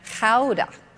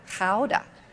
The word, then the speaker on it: Gouda: And we were also going to go see Gouda, or as they pronounce it in Amsterdam, Gouda. Gouda.